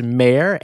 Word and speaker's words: Maher: I believe it's pronounced Maher, M-A-H-E-R.